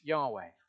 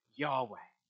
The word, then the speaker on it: Yahweh: In Hebrew, it's just four letters, YHWH, probably pronounced Yahweh.